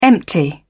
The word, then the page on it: empty: British English